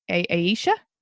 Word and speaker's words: Aisha: A-I-S-H-A. How do you say that? Aisha? – A-I-S-H-A? Aisha, yeah.